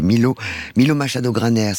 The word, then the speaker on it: Milo Machado Graner: Vous avez choisi un super acteur qui était dans La Palme d'Or l'an dernier, c'était Milo Machado Graner, c'est comme ça qu'on prononce ? – Oui.